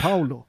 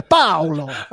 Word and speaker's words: Paolo: Ja, men jag, säger, jag är ju född i Sverige, jag säger Paolo. Ja. Jag heter inte det. Jag heter Paolo. Paolo! Paolo! Che cosa fai? Madonna! Statten un poco fermo! Paolo! Paoluccio! Så att jag, jag, jag, jag uttalar på svenska.